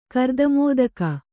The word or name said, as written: ಕರ್ದಮೋದಕ